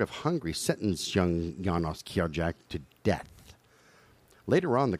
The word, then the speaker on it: Kirjak: K-I-R-J-A-K? Can we agree on Kirjak? No, his last name wasn't Mihoff. – No, spell it again. – K-I-R-J-A-K. Kirjak? – Yeah, I'll agree with it.